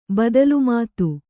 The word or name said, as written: ಬದಲುಮಾತು